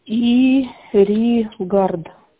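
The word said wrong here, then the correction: regard: да? – Регард. – А, регард. – Регард.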